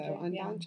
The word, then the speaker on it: Ondaatje: I think Ondaatje. So. Ondaatje. Yeah. It has that funny T in it.